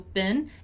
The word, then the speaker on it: been: Once again, attention to the pronunciation of been, right? Which is different from being, okay? So been, it's been a long week.